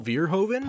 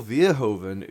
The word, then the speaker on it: Verhoeven: Paul Verhoeven? – Paul Verhoeven, who also did...